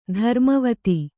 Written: ಧರ್ಮವತಿ

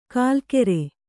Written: ಕುಲಕರ

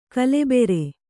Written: ಗೋಲಿಬಾರು

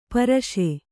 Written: ಪೌರುಷ

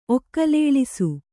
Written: ಒಕ್ಕಲೇಳಿಸು

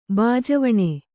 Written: ಬಾಜವಣೆ

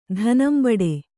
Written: ಧನಂಬಡೆ

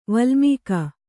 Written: ವಲ್ಮೀಕ

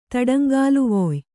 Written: ತಡಂಗಾಲುವೊಯ್

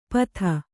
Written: ಪಥ

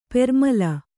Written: ಪ್ರೇಮಲೆ

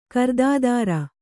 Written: ಕೃತ್ತಿಧರ

